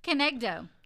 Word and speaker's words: kenegdo: Kenegdo.